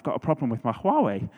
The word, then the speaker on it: Huawei: It's a Huawei, is how I pronounce it. And some people say Hawaii.